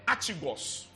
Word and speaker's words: archegos: The word author is from the Greek word archigos. Archigos. – Archigos. – It is spelled A-R-C-H-E-G-O-S.